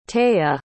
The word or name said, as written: taya